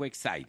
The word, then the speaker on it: Exide: Así se escribe Exide, se pronuncia Exide.